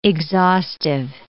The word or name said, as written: exhaustive